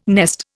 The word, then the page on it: nest: Indian